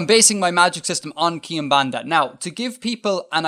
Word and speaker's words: Quimbanda: Yeah, I believe it's pronounced Kimbanda. – I don't know. How do you spell it? – Q-U-I-M. – Yeah. – B-A-N-D-A. – B-A-N-D-A. Kimbanda. I don't think I do.